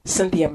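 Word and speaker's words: Synthia: join me on Cynthia McIntosh Ministries in YouTube.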